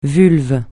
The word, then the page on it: vulve: francais